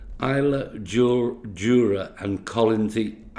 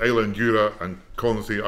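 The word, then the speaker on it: Islay, Jura and Colonsay: However, I'm going to ask how he got involved with the show, and am I correct in the pronunciation of the show? – Good afternoon. Yeah, you're correct. It's the Isla Jura and Collinsy Agricultural Association show, which is on the 11th of August this year.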